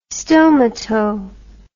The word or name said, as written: stomato